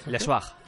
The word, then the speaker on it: Le Soir: Él trabajaba eh, en, eh, eh, trabajó durante, durante la ocupación nazi, trabajó en Le Soir, ¿lo digo bien? ¿Vale? Sí. – Le Soir.